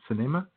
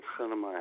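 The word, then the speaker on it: Sinema: and they've done their morning update, or mid-morning update, and Kirsten Cinema's, I guess that's how she pronounced her name, Cinema.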